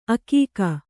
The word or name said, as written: ಆಗ್ಗೆ